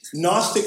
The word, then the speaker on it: Gnostic: Gnostic thought, that's spelled G-N-O-S-T-I-C, silent G.